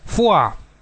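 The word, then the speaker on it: Foix: Написано, например, «Фо-и-икс», название катарского города, недалеко от Тулузы, а читается «Фуа».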